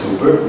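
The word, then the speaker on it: hooper (ὑπέρ): Now, according to Strong's Greek lexicon, that Greek word hooper up there, pronounced hooper, means over with the object in the genitive case.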